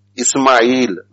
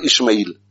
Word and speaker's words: Ismail: And similarly, when we give a good name to somebody and thereafter we mention the whole name, pronounce it correctly. Ismail, not Ishmael.